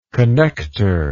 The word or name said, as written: connector